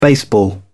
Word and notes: baseball: British English

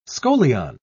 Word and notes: scolion: British English

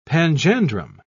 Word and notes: panjandrum: British English

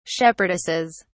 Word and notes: shepherdesses: British English

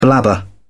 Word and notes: blabber: British English

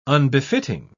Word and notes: unbefitting: British English

